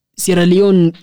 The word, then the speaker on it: Sierra Leone: Sierra de Leone. – Oh, that's how it's pronounced. – Sierra Leone,